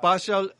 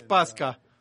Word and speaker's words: Pascha: que muchas veces es mal pronunciada como Pashal o Pasha, pero no es Pasha, es Pascha.